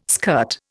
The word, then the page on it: skirt: Indian